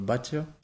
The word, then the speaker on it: Batlle: Alors, il y a ceux qui ont un accent un peu plus prononcé, ils diront peut-être euh, Batia. À Perpignan, comme je te disais, ça, va, ça va plus ou moins.